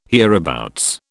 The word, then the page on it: hereabouts: British English